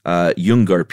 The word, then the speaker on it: Nyoongar: What I landed on was in Western Australia, these were the Yungar people. – Very nice, Chuck. – Spelled Nyoongar.